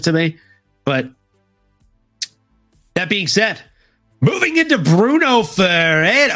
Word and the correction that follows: Ferreira: However, when it comes to somebody like Bruno Ferreira, we make an H sound for the R.